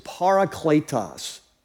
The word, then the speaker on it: parakletos: So the word is parakletos, parakletos. And here's how you spell it. It's P-A-R-A, para. And then the second is kletos, K-L-E-T-O-S, parakletos.